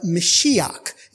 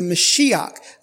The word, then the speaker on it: Mashiach: Now, Messiah is how we pronounce that in our English vernacular. It's a Hebrew word pronounced, and I'll probably mess it up, but Mashiach. It's the Mashiach is the Hebrew pronunciation. Probably the ancient pronunciation of the Hebrew was a little bit different than you would hear now. But anyway, Mashiach.